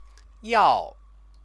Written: yao

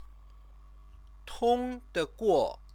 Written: tongdeguo

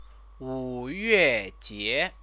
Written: wuyuejie

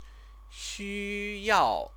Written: xuyao

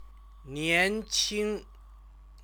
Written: nianqing

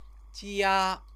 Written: jia

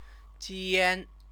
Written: jian

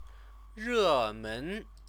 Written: remen

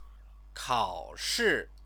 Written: kaoshi

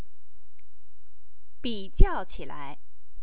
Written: bijiaoqilai

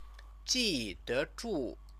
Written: jidezhu

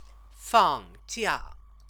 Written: fangjia

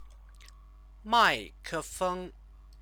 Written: maikefeng

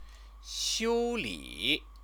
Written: xiuli

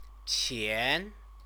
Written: qian

